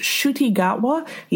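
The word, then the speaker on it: Ncuti Gatwa: It's pronounced Shuti Gatwa.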